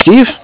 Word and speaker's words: Steve: My name is Steve.